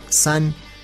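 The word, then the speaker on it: san (伞): Lo mismo sucede con un inofensivo paraguas, una sombrilla, que en chino se pronuncia san, muy similar a la palabra san que significa separación.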